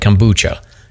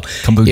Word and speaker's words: kombucha: And she's like, yeah, but you can't emphasize the A. Just say it quickly. And I was like, kombucha. And she's like, okay, that's okay. So kombucha. If I messed up the pronunciation, I'm blaming my daughter, Megan.